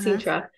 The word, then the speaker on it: Sintra: Sintra. So I uh-huh. – Sintra, and so I got off the plane.